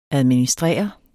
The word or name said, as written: administrere